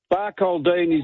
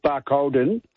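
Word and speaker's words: Barcaldine: Barcoldeen is pronounced Barcolden in Queensland.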